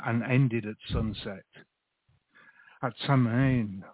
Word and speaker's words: Samhain: So let me just say to anybody who's listening, the topic for our show is Samhain, pronounced Samhain.